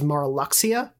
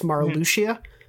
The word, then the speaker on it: Marluxia: I always knew their name as Marluxia because I just thought you pronounced the X that way, but it's Marluxia. Marluxia.